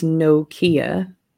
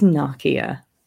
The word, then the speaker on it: Nokia: No, Nokia is mostly pronounced in India as Nokia. – Yeah. – But the right way to say Nokia is Nokia.